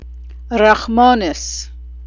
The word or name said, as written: rachmonis